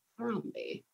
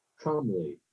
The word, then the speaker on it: Thrombey: Sounds right to me, based on what Thrombey. I see. Thrombey. It's Thrombey. That's awesome. Cool. Sorry. – Trombley. – It's not Trombley.